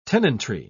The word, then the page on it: tenantry: British English